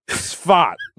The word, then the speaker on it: Safed: So this guy, was born October 11th, 1542, in Sfat, which is spelled S-A-F-E-D, but it's pronounced like a fart, Sfat.